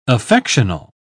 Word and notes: affectional: English; British English

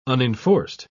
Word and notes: unenforced: British English

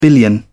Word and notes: billion: British English

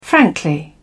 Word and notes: frankly: British English

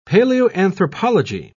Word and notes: paleoanthropology: British English

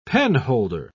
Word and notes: penholder: British English

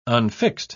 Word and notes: unfixed: British English

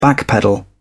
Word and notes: backpedal: British English

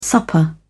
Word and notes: supper: British English

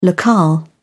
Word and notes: locale: British English

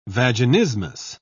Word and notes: vaginismus: British English

